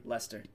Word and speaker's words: Leicester: That's like Leicester, how do you say their name? – Leicester.